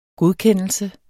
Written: godkendelse

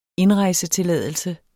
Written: indrejsetilladelse

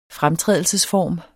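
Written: FREMTRÆDELSESFORMEN

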